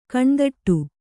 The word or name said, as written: ಕಣ್ತಡೆ